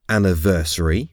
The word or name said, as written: anniversary